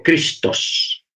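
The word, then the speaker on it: Cristos (Χριστός): La palabra Cristos, porque así se pronuncia en griego, Cristos, no Cristo, sino Cristos, es una palabra que en griego significa ungido.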